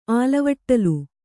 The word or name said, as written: ಆಲಿವಟ್ಟಲು